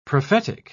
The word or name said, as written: prophetic